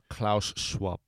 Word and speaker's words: Klaus Schwab: But this book is called The Fourth Industrial Revolution by, you probably could say his name better than me, Klaus Schwab.